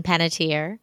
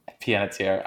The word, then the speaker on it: Panettiere: How do you say her last name? – Hayden Panettiere. – Panettiere. I've never said that aloud.